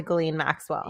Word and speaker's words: Ghislaine: What the fuck happened to Ghislaine Maxwell? – Yes.